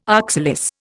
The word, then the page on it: oxalis: Indian